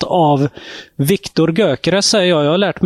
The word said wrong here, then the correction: Gyökeres: Hur ska man uttala det? – Ja, det är ju ungerskt ursprung. Gyökeres. – Gyökeres, ja.